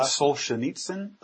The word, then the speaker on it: Solzhenitsyn: Is that Solzhenitsyn? – That sounds good to me.